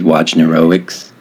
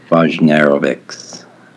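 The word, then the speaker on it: Wojnarowicz: Yeah, I think for a long time I was pronouncing his name David Vojnarovics, but then people were like, no, the W is like a V sound, dummy. So I was like, okay, David Vojnarovics.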